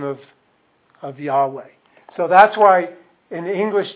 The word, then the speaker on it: Yahweh: Yahweh is what we think it should be pronounced like.